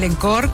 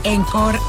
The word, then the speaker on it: encore: Encore, acuérdense que el encore o el encore, como decimos nosotros, no se dice encore en francés, encore.